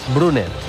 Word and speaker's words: Brunner: Paris Brunner, delantero centro alemán, juega en el Mónaco. – ¿Quién? Repite, por favor. – Paris Brunner, con dos N's, Brunner.